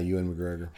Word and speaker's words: Ewan McGregor: Yeah, Ewan McGregor.